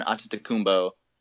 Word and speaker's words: Antetokounmpo: Giannis, the unicorn, Antetokounmpo.